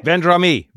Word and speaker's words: Vendrame: With Andrea, you're going to have to help me with this pronunciation because you're, you know, Mr. Fancy commentator. Andrea Vendrame? – Vendrame. Vendrame, yeah.